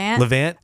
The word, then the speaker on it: Levant: Treorgy, family orchards in Levant. Is that how you say it? – Levant as the best pumpkin patch in the nation.